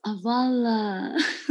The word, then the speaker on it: Avala: Tell me about, and I hope I pronounce this correct, is it Avala? – Yes, Avala.